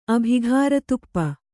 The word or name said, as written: ಅಭಿಘಾರತುಪ್ಪ